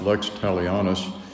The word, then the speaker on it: lex talionis: It's pronounced lex talionis.